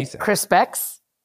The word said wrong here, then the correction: Crispix: How do you say it? – I say Crispix. – There's no way it's Crispix. – Yeah, because it's C-R-I-S-P-I-X, Crispix. – Yeah. You might be right.